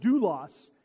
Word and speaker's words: doulos: So beginning in Romans 6:16, top of the back page, the words used for slave that are used in the text here, the word is pronounced doulos in the Greek, can also be translated as bondservant or a servant.